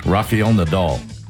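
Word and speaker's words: Rafael Nadal: We've got Raphael Nadal turning 40 today. Am I saying it right? – Mm-mm. – Okay, then say it. – Raphael Nadal.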